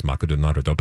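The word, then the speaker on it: Makudonarudo (マクドナルド): So McDonald's, Makudonarudo.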